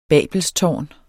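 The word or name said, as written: BABELSTÅRNET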